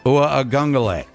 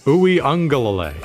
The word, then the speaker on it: Uiagalelei: DJ Uiagalelei. – You said it. Uiagalelei.